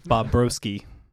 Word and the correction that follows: Bobrovsky: Close. It's Bobrovsky.